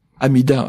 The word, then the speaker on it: Amida: Amida, à prononcer la japonaise, c'est Amitabha en sanskrit, ou Omitwo en chinois.